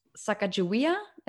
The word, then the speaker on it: Sacagawea: Um, you will see, I think, Sacagawea.